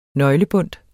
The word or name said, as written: nøglebundt